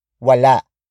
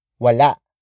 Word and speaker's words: wala: Wala. Wala. Wala is spelled as W-A-L-A.